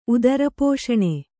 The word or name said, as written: ಉದರಪೋಷಣೆ